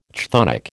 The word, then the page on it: chthonic: American English